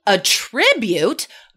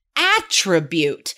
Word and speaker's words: attribute: What's the difference between attribute, verb, second syllable stress, and attribute, noun, first syllable stress?